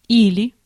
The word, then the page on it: que: /kə/